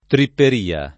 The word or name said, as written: tripperia